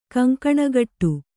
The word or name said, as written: ಕಂಕಣಗಟ್ಟು